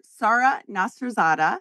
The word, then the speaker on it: Sara Nasserzadeh: Our guest is Dr. Sara Nasserzadeh.